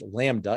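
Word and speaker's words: LaMDA: it's pronounced Lambda, it's big L, little a, MDA,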